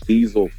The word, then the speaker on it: Deezil: You can check out my new comedy EP on all major platforms called Deezil Fuel. That's spelled like my name, D-E-E-Z-I-L.